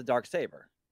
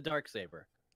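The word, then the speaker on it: Darksaber: As opposed to the dark saber. Dark saber. – I'm sorry. – The emphasis on saber.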